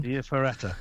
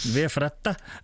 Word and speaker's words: Via Ferrata: the famous Via Ferrata. How's that? Is that all right? – Yeah. – Pronunciation? – Via Ferrata, yes. – Via Ferrata.